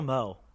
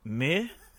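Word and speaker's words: MØ: My girl Mo, which is actually not pronounced Mo, it's pronounced Meh.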